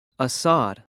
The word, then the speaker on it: Asad: F-L-O-R-E. – 5. – What's your name? – Assad. – How do you spell that? – A-S-A-D.